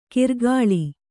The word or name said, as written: ಕಿರ್ಗಾಳಿ